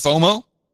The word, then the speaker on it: FOMO: Yes, and FOMO is pronounced FOMO.